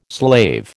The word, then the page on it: slave: American English